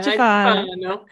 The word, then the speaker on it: Iaciofano: Iaciofano.